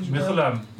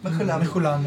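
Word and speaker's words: Mechoulam: Michulam. – Michulam, yeah.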